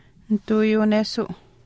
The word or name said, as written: nituuyuunesuu